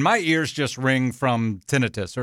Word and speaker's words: tinnitus: The true definition or the true pronunciation is... Tinnitus.